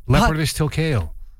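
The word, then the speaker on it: Leopardus tilcayo: And they've given it the name Leopardus tilkeo. – I love when you have to say hard words. – It's not a hard word to say. Leopardus tilkeo. – Tilkeo? – Yeah. T-I-L-C-A-Y-O.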